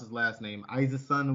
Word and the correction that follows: Esiason: or whatever. – Oh, Boomer Esiason. – Yeah, wow. Boomer Esiason.